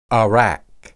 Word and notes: arak: Hebrew

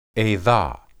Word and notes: edah: hebrew

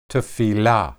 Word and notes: tephilah: hebrew

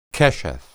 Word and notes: qesheth: hebrew

